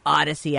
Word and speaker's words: Audacy: I mean, listen, we're a radio station, but you can get the stream all things audio available on the Audacy app. It's spelled A U D A C Y. Kooky, right? Because it sounds like the word Odyssey, but then it's audacious and audio and all things audio.